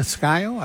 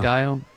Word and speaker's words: Scio: Technically, how's it pronounced? Scio Township? S-C-I-O? – Yeah, isn't it Scio? I believe, yeah. – Scio? – Scio.